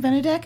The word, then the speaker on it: Benedek: Benedek.